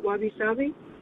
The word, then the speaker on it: wabi-sabi: Wabi-sabi. Is that how you say it? Wabi-sabi? – Yes.